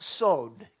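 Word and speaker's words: sod (סוד): Well, so God is telling you and me in Hebrew, that word confides is pronounced sod in Hebrew. Sod.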